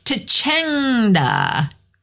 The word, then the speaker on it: tacenda: It's tachenda. Tachenda. Tachenda. Spelled T-A-C-E-N-D-A. Tachenda. It looks like tachenda, but it's pronounced tachenda.